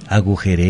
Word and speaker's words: agujereé: Con acento en no la es última agujerie. E o sin acento. – No. Es agujereé. – ¿Con acento? – Con o acento, sí, con acento última. En la última E, uh-huh.